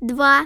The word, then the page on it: 2: Child